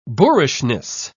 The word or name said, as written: boorishness